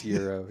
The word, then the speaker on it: gyro: It's gyro, yeah. You don't pronounce the G at all.